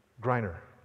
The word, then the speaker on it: Griner: Brittany Grenier, did I say her name right? The Baylor basketball player, somebody help me out here. Is it Grenier? Griner, whatever. Tall, good, good basketball.